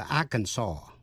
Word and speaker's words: Arkansas: Tên tiểu bang A-R-K-A-N-S-A-S, có âm S câm, đọc là Arkansas. Arkansas, chứ không đọc là Arkansas.